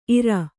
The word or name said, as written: ಇರೈ